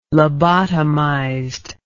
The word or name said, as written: lobotomized